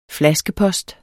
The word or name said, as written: flaskepost